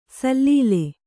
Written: ಸಲೀಲ

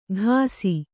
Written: ಘಾಸಿ

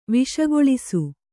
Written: ವಿಷಗೊಳಿಸು

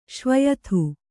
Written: ಶ್ವಯಥು

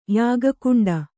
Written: ಯಾಗಕುಂಡ

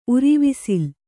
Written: ಉರಿವಿಸಿಲ್

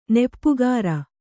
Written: ನೆಪ್ಪುಗಾರ

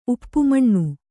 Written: ಉಪಮಾನ

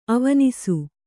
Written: ಅವನಿಸು

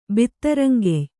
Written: ಬಿತ್ತರಂಗೆಯ್

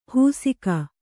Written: ಹುಸಿಕ

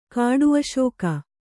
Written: ಕಾಡುಅಶೋಕ

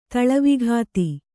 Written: ತಳವಿಘಾತಿ

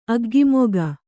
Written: ಅಗ್ಗಿಮೊಗ

